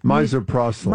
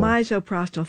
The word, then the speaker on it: misoprostol: Misoprostol. – Misoprostol.